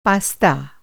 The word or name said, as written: पास्ता